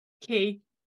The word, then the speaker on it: Quay: Quay. Pronounce it. – Key. – Yeah. – Quay, though, yeah. – Quay. She was today years old when she realised it was pronounced key.